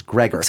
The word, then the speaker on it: grogger: No, I never heard Gregor. I only know from Grogger. – Canada. So this letter writer, Andrea Safer, says the Canadian pronunciation is Gregor.